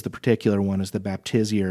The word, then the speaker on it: Baptisia: And so sometimes these might be labeled Baptisia, or how do you pronounce it? – Oh, I just said Baptisia.